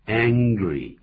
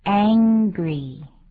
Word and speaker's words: angry: Angry, 因为有一个 G, A-N-G 在后头把 G 的音啊。所以那个 an 的时候念成有嗯的鼻音出来啊。 – 没错。 – 好，我们再把这个字念一下。 – angry, angry. 有些人念错，念作 angry, 不是这么念的，就是个舌根音 angry。